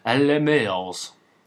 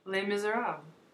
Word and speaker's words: Les Misérables: We're gonna take a little break, come back, and we're gonna talk about Les Mills. – No. – What, we can't call it Les Mills? – No, that's awful. – Say it right then. – Les Misérables.